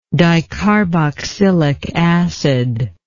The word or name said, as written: dicarboxylic acid